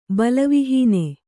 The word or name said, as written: ಬಲವಿಹೀನ